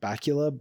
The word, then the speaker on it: Bakula: I think he pronounces it Bakula, but. – Yeah. Dracula.